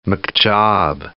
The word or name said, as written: McJob